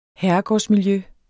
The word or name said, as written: herregårdsmiljø